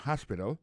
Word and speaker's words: hospital: L'hôpital c'est comme ça on dit ça en anglais, hospital. Alors accent tombe sur premier syllabe, il y a trois syllabes, puis she likes to visit hospital. Hospital l'a écrit H-O-S-P-I-T-A-L, hospital, l'hôpital.